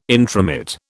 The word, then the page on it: intromit: British English